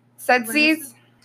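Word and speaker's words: Tzetzes: I think it's pronounced Setzies? – Mm-hmm, that looks right. – Setzies? – Oh, yeah.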